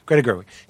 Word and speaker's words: Gerwig: Did I get her name right? Greta Gerwig? Yeah. Greta Gerwig.